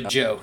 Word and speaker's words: Zhou: It's actually Joe. They essentially pronounce Joe. – Oh, Joe? – Yep. – Like J-O-E?